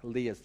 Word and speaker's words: Leah: So Leah, I keep saying Leah. I had a friend called Leah once. I'm going to bring it back. Leah's the correct pronunciation. Leah.